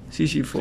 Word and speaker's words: Sísifo: Eh, no sé cómo se dice Sísifo, Sísifo, ¿no? – Sísifo, ¿no? Sísifo. Sí, está ah, hay una serie en Netflix.